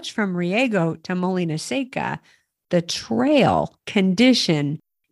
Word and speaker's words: Molina Seca: Molina Seca. It's Molina. A dry mill. Molina Seca. – Molina Seca.